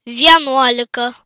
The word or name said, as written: 11